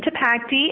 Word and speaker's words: Tipacti: My name is Evelyn Tipacti, and the email is evelyn.tipacti, and I'll spell that for you, T-I-P-A-C-T-I.